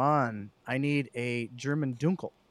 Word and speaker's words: Dunkel: It's pronounced Dunkel. – No, he says, I want to thank you for pronouncing it correctly. It's a Dunkel.